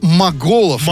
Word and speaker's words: Моголов: Моголов, не путать с монголами.